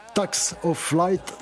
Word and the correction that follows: Facts of Life: Facts of Life. – Voilà, je l'ai mal prononcé, désolé.